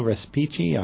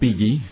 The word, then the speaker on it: Respighi: It's Ottorino Respighi. I hope I didn't butcher that. – Respighi. Close. – Respighi, okay.